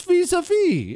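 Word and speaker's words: vis-à-vis: It's vis-a-vis. It's not it's vis-a-vis. The S is silent. It's French.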